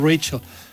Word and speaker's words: Rachel: En Estados Unidos no le pueden decir Johnny, tienen que decir Juanito, pues aquí le decimos Rachel, porque hay que pronunciarlo en el idioma en que, se lo pusieron.